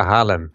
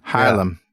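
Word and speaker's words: Haarlem: We were in Harlem. Is that how you say it? – Yeah, Harlem. – Harlem. Yeah, yeah, it's Harlem like New York, but with two A's.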